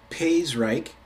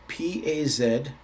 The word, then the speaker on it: Pazyryk: In the, I believe it's pronounced Paizryk, Pazyryk, the Paizryk tombs, mummies were discovered that were quite elaborately tattooed.